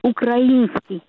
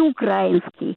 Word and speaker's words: украинский: Это вот слово «Украина», да, и «украинский». А не украинский. – Yeah. – Вот ударение.